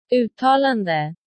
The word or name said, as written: uttalande